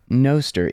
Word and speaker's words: Noster: I always heard Noster, N-O-S-T-E-R, in Latin pronounced no, like in notes.